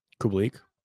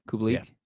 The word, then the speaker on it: Kubalik: Kubalik. – Is that how you say it? Kubalik? – Yeah.